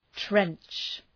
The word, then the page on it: trench: trentʃ